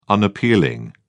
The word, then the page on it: unappealing: British English